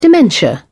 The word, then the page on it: dementia: British English